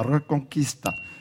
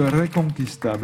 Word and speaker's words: Reconquista: La Reconquista. – Oui, la Reconquista. Je crois que c'est comme ça qu'on prononce. Hein. On roule un peu l'air au début, Reconquista, mais je ne suis pas absolument certain de la prononciation absolument parfaite.